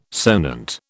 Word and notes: sonant: British English

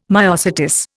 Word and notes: myosotis: Indian

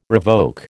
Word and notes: revoke: American English

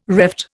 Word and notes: reft: Indian

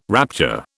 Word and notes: rapture: British English

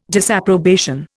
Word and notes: disapprobation: Indian

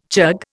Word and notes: chug: Indian

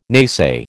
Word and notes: naysay: American English

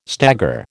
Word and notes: stagger: American English

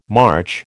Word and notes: March: American English

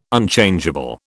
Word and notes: Unchangeable: British English

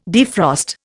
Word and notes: defrost: Indian

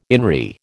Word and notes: in re: American English

